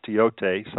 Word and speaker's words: Thoth: So the second card is it says right, and it is the Egyptian god Teote, which is spelled T-H-O-T-H, pronounced Teote. Some people say Thoth, but okay.